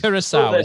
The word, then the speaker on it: Curaçao: Curaçao is how it's pronounced.